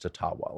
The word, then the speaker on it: Ottawa: And this First Nations guy is like, no, actually, sorry, white Canada, but it really is pronounced Ottawa. Like, that's the Ojibwa pronunciation.